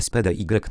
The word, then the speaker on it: SPDY: Protokół SPDY, nazwę tę wymawia się SPD, czyli szybki.